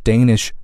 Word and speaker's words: Danish: 注意丹麦的这个词变成了形容词之后，它的那个 a 字母呢发生了变化啊，不再读 Danish 而读作 Danish 啊，双元音 a Danish territory 啊，丹麦的一块领土。